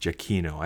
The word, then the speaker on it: Giacchino: And I just, Mark helped me, for example, to remember that Michael Giacchino's name is pronounced Giacchino.